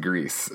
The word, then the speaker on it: Gris: Okay, I looked it up on the Reddit AMA for this game, and the developers said it's pronounced Greece. So okay, like the country or the movie.